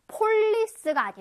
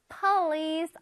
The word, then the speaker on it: police: 폴리스가 아니라 police officer. 양옆으로 쫙 늘려서 해주는 거 잊지 마세요.